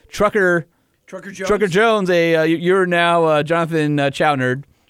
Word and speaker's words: Chouinard: It's Chouinard. – Chouinard. – Chouinard. – Okay. – Yeah. It's spelled C-H-O-U-I-N-A-R-D.